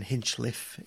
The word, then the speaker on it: Hinchliffe: Ben, it's got to be pronounced correctly, Ben Hinchliffe. It's not Cliff, as the commentators say, which does annoy us all.